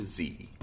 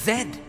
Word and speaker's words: Zed: I'll make sure this operation runs smoothly from A to Z. – Oh no. The thieves' dialect. Ether says Z when it's pronounced Zed.